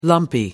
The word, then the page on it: lumpy: American English